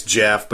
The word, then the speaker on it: Geoff: Is it Geoff or Jeff? – It's Jeff, but it's spelled Geoff.